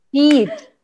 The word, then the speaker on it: sheet: Dapat balance sheet with a long E. – Okay. – In the S-H-I-T.